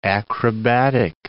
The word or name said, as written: acrobatic